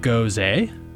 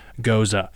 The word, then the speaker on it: Gose: Goes A? Goes? Goes A? Which one is it? Well, as I alluded in the episode intro, it is properly pronounced as Gose.